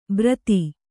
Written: ಭರತಿ

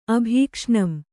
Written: ಅಭೀಕ್ಷ್ಣಂ

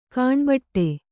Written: ಕಣ್ಬಿಡು

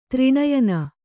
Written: ತ್ರಿನಯನ

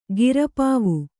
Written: ಗಿರಪಾವು

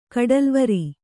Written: ಕಡಲುವರಿ

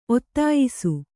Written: ಒತ್ತಾಯಿಸು

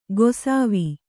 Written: ಗೊಸಾವಿ

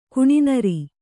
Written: ಕುಣಿನೀರು

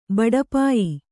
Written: ಬಡಪಾಯಿ

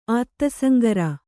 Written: ಆತ್ತಸಂಗರ

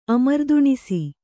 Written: ಅಮರ್ದುಣಿಸು

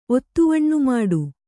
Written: ಒತ್ತುವಣ್ಣುಮಾಡು

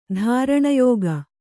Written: ಧಾರಣಯೋಗ

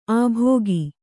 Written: ಆಭೋಗ

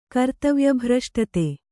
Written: ಕರ್ತವ್ಯಭ್ರಷ್ಟತೆ

